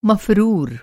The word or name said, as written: मफ़रूर